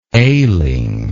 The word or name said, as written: ailing